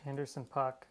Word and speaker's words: Anderson .Paak: And it's, I never know if I'm saying this right, but it's Anderson .Paak. Is that correct? – Anderson .Paak.